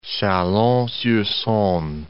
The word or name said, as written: Chalon-sur-Saone